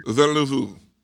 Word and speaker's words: Villeneuve: No, I do know it because people got really mad at us on the Dune podcast and wrote in several times with clips of how to pronounce his name. Denny Villeneuve.